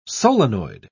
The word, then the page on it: solenoid: British English